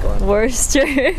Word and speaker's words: Worcester: Worcester. – Like Woo Sox.